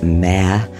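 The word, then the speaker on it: Maere: M-A-E-R-E. I think it's pronounced Mare.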